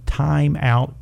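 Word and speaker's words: Thyme Out: Now, we have a product called Time Out, but it's spelled T-H-Y-M-E because that is an herb.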